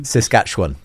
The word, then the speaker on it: Saskatchewan: Marty in Saskatchewan, I think that's how you pronounce it. Saskatchewan. – Saskatchewan, that's yeah, the one.